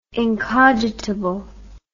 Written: incogitable

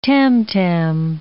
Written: tam-tam

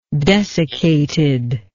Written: desiccated

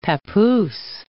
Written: papoose

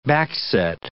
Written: backset

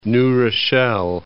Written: New Rochelle

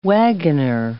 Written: wagoner

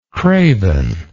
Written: cravenness

